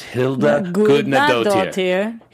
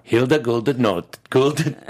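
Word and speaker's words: Hildur Guðnadóttir: To get Hilder Gudnadottir. Gudnadottir. Gudnadottir. Gudnadottir. – Hilder Gudnadottir. – I'm adding four extra syllables. It's Hilda Gudnadottir. Hilda Gudnadottir.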